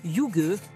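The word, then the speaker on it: hygge: Un petit rituel inspiré de ce mot déconcertant qui se prononce yuge, l'art de vivre à la danoise.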